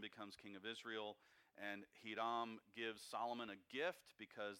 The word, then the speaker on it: Hiram: Most Americans pronounce it Hiram. – Hiram, yeah. – It would be pronounced Hiram.